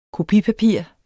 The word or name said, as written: kopipapir